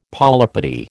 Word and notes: polypody: American English

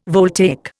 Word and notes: voltaic: Indian

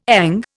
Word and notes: ENG: Indian

